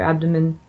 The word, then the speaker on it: abdomen: They're just very womb-centric, the women there, and they see you jamming your elbows into your abdomen, your abdomen. I'm pronouncing like I'm a foreigner.